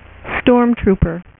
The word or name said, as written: stormtrooper